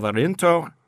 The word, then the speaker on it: Arinto: The white is a blend of Arinto and Antão Vaz.